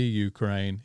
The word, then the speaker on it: Ukraine: And it's not the Ukraine, it's Ukraine. – Ukraine. It was the Ukraine when we were under Soviet rule.